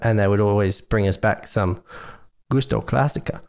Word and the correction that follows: Classico: Gusto Classico.